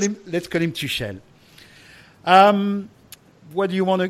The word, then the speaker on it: Tuchel: Another big hot topic is Thomas Tuchel, which is apparently the right pronunciation.